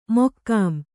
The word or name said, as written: ಮಕಾಂ